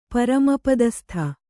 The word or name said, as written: ಪರಮಪದಸ್ಥ